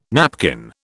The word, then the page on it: napkin: British English